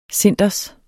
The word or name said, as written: cinders